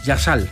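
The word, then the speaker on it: Yakhchal: Y vamos a ver si lo pronuncio yo bien. El Yaxchal. – Yaxchal.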